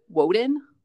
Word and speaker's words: Woden: you would actually pronounce it Woden. W sound. So Woden is Wednesday. It is Woden's day.